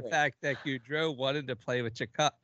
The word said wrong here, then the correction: Tkachuk: With Chicot. I can't yeah. even say his name. – Chick Matthew Chikot. Chychrun. – No. Tkachuk. Tkachuk. – Tkachuk. – Oh. – Tkachuk. Tkachuk. Tkachuk. yeah, Gaudreau wanted to play with Tkachuk.